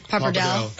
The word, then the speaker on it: Pappardelle: Pappardelle.